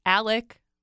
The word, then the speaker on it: Alex: is it's Alec Murdaugh. – Okay. – Even though it's not how it's spelled. – No, and that really bothers me that it's Alec, but it's spelled like Alex. – And what about, yes, and it's also that everyone, obviously, most people pronounce it Alex, which, why wouldn't you?